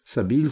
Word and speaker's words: Sabeel: Sabil, ça s'écrit S-A-B-E-E-L.